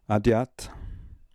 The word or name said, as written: Adiat